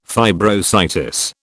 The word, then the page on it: fibrositis: British English